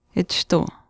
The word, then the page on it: achishtuu: Chisasibi